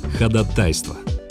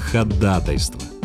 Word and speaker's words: ходатайство: Пожалуй, ходатайство. А если по-русски, то, конечно, ходатайство. И только так, с ударением на второй слог.